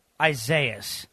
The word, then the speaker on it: Isaias: Isaias. – Hurricane Isaias.